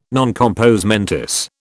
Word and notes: non compos mentis: British English

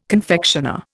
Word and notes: confectioner: Indian

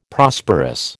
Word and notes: Prosperous: American English

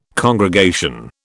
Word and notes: congregation: British English

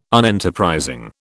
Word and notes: Unenterprising: British English

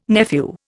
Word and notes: nephew: Indian

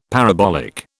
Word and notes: parabolic: British English

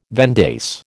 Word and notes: vendace: American English

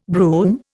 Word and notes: brume: Indian

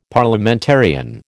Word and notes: Parliamentarian: American English

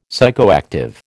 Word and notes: psychoactive: American English

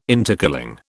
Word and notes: intercooling: British English

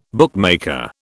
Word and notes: bookmaker: British English

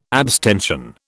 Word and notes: abstention: British English

